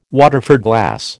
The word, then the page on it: Waterford glass: American English